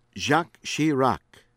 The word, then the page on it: Jacques Chirac: American English; from France